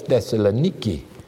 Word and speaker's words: Thessaloniki: I like the way they pronounce it over there. They got the airport. They call the place Thessaloniki. – Okay. – And this is Thessalonica, as some people would say.